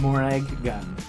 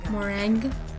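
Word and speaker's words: Morag Gunn: Morag Gun. – Morag Gun. Okay. – But no, see, there's two G's, so you have to you have to release the consonant. – Morag Gun. – Morag Gun.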